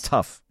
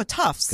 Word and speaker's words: Tufts: Not Tufts. – No, Tufts.